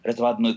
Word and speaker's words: разводной: А во всех остальных случаях, если это прилагательной бывшей причастия образованный глагол «разводить», то ударение должно падать, конечно же, на окончание. Разводной ключ, разводной мост, и в этом смысле улица должна быть разводная.